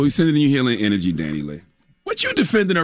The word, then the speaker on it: Dani Leigh: She's also been dealing with people saying her name wrong. It's Dani Leigh.